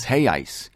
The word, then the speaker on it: reais: It's spelled R-E-A-L, but it's real. The plural is reais.